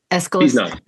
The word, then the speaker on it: Aeschylus: And I feel like Aeschylus, do I say his name right? – Aeschylus. – Aeschylus.